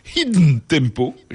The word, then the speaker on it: Hidden Tempo: Eh bien, je voulais en venir à la start-up suédoise Hidden Tempo. J'essaye de prononcer avec l'accent suédois. – C'est parfait,